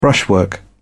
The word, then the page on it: brushwork: British English